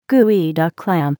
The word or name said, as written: geoduck clam